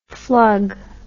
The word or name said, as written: flug